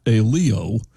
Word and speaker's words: Aiello: Fats Aiello, although he pronounced it A-Leo, and he spelled his name A-I-E-L-L-O.